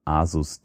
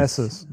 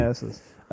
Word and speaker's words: Asus: Aber bei den ganzen anderen, ob es jetzt irgendwie Asus, D-Link, Asus. Was waren die billigen? Asus, wenn wir schon bei korrekter Aussprache sind, ja. – Sorry. – Asus.